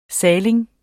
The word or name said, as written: saling